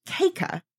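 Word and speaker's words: Kaca: Except I watched the press conference late into my research, and that's when I found out that it's actually pronounced Caca.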